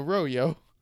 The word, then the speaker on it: Rollo: So in like Spanish, you know, Royo. That is how Jesus. You pronounce it if you were Spanish.